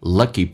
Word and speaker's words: lucky: Iman 有一个连音 Iman， and 跟 incredibly 有一个连音叫 incredibly，前面两个单词用降调来发，变成 I'm an incredibly lucky person. Lucky 美式发音发成 k，发成 g， lucky person who have come from a family，来自于一个什么样的家庭。